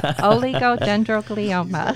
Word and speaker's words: Oligodendroglioma: Oligodendroglioma.